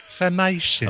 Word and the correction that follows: Filmation: I'm being told. I'm being told that's filmation.